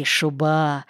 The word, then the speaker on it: шуба: «Ну вот и шуба, ну вот и нормально». «Шуба» с ударением на втором слоге значило «хорошо», «отлично».